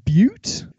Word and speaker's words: Butte: It is right next to Elephant Butt, which may be pronounced Butte.